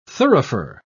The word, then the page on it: thurifer: British English